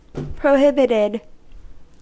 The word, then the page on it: prohibited: American English